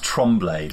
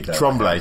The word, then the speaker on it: Tremblay: Apparently pronounced Tremblay. – Tremblay, Jacob yeah.